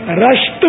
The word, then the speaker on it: رشت: اچھا وہ صحیح تلفظ جو ہے نا, امی جان کا مزار, غوث پاک کی امی جان کا مزار, وہ ایران کے صوبہ گیلان کے صدر مقام رشت میں ہے. راہ پر زبر بتا رہے ہیں جی رضا, رشت شہر ہے.